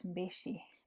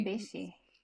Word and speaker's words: Mbichi: It's pronounced Mbichi. – Mbichi. Oh, sorry. – Mbichi.